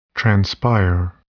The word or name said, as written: transpire